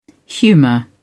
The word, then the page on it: humor: British English